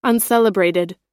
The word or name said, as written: uncelebrated